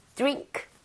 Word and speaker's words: drink: 그래서 드링크가 아니라 이 모양 좀 모아서 drink 이렇게 가는 게더 자연스럽죠.